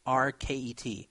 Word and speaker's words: Skatteverket: The Swedish tax authority, which again is the equivalent of the IRS, I guess it's called a Skatteverket. I don't know if I'm pronouncing that right. Skatteverket. It's Skatteverket. Skatteverket.